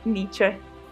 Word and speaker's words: Nietzsche: Eppure tutti possiamo confermare una cosa, che ci viene riportata da un filosofo, filologo ed anche compositore dai grandi baffi, Nietzsche. – Dai grandi baffi, ma soprattutto dal cognome complesso. Infatti si scrive N, I, E, T, Z, S, C, H, E, Nietzsche Comunque, nell'opera Crepuscolo degli idoli, Nietzsche scrive: Senza musica la vita sarebbe un errore credo sia proprio impossibile dargli torto.